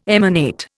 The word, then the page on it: emanate: Indian